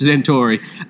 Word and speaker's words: Centauri: Everyone pronounces his name differently in the movie, which is really interesting. But I believe it's Centauri, and played by Robert Preston, veteran actor Robert Preston.